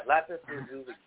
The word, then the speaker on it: lapis lazuli: I got this weird one I can't pronounce. I keep forgetting. Lopsol something. I forget what it's called. It's like a blue. – Oh, yeah. Lapis lazuli.